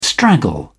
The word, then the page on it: straggle: British English